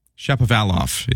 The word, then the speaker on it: Shapovalov: Shapovalov is how you say Denis Shapovalov's name as he plays at Wimbledon today.